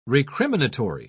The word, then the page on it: recriminatory: British English